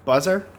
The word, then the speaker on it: BUZZR: On the Buzzer Network. – Yeah. – If you go on YouTube and you go BUZZR is the name of the channel, Buzzer.